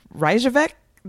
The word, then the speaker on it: Reykjavik: I want to go to Reykjavik. Did I say it right? – Oh, you did.